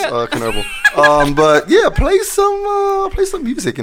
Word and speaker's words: Chernobyl: It's actually Chernobyl. – Is it Chernobyl? – Is that how they pronounce it? – It's Chernobyl.